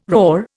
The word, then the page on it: roar: Indian